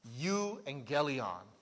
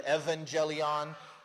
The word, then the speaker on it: euangelion: And in fact, this is the word where we get, if you've ever heard the phrase an evangelical Christian, because sometimes we pronounce the U as a V, so it'd be the evangelion or the euangelion.